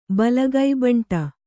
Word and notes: ಬಲಗೈಬಂಟ: kannada

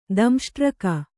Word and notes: ದಂಷ್ಟ್ರಕ: Kannada

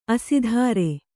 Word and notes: ಅಸ್ತ್ರಿ: Kannada

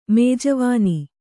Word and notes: ಮೇಜುವಾನಿ: mējuvāni

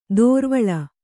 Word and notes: ದೋರ್ವಳ: dōrvaḷa

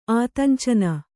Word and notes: ಆತಂಚನ: Kannada; ātañcana

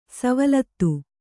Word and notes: ಸವಲತ್ತು: savalattu

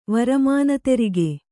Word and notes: ವರಮಾನತೆರಿಗೆ: kannada

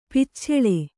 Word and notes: ಪಿಚ್ಛಲ: picchala

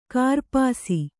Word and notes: ಕೂರ್ಪಾಸ: kūrpāsa

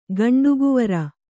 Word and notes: ಗಂಡುಗುವರ: Kannada

